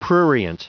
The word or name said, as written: prurient